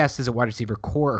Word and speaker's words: Corps: The S is silent and it comes out to just be core. – It is corpse as in Peace Corps or United States Marine Corps.